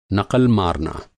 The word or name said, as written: नक़ल